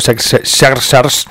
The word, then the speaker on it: Xerxes: Bueno, ya anunció que sí va a ser el título, Xerxes. Bueno, Jerjes, son las dos formas de pronunciar este nombre,